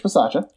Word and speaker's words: Bisaccia: Rich Bisaccia.